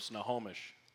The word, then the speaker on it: Snohomish: Yeah, I'm from Snohomish. – Snohomish. So, where the heck is Snohomish? – Snohomish is about an hour 15 northeast of Seattle, Washington. – So. Okay, tell me about Snohomish.